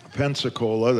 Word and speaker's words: Pensacola: rhymes with Coca-Cola, Pensacola, that is.